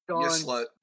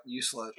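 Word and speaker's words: Yseult: It's Y-S-E-U-L-T. – Yuslet. – Yuslet? – Yep, Yuslet. – Sure. – Okay. Can you say that one more time for me? – Yuslet.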